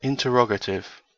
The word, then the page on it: interrogative: British English